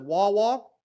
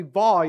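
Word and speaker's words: Vav: And then you get down to verse 44, and you have Va, even though it says Wawa, it's actually Va. You pronounce it with a V sound, Va.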